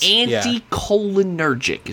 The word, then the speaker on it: anticholinergic: cholinergic delirients. – Anticholinergic is how you pronounce that one.